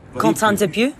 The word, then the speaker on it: Quentin Dupieux: In France, we say Quentin Dupieux. – Oh, Quentin Dupieux.